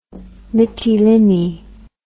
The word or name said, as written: mitilini